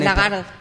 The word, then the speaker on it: Lagarde: Por la Lagarde. ¿Cómo es que se pronuncia esto? Bueno, en Lagarde. Pues la señora Lagarde.